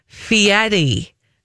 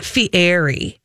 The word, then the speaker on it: Fieri: She said, could you please pronounce Guy Fieri's name correctly? He pronounces it Fieri, not Fieri.